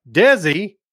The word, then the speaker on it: DESI: DESI, pronounced like Desi Arnaz's name.